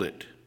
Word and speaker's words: Ai: But yet you have one chapter later, and there's a little small village of Ai. Some people pronounce it I.